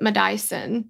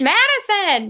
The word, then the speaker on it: Madyson: And she pronounced it Madison. – Oh. – Like... almost like my Dyson blow dryer, like I'm a Dyson, but it was really funny. I was like, it's Madison.